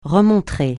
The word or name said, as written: remontrer